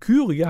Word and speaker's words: Kyrie: Und Kyrie hat ja die Betonung auf der Eins.